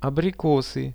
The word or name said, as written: abrikosy